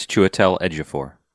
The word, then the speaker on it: Chiwetel Ejiofor: By the way, that guy you mentioned, his name is Chiwetel Ejiofor.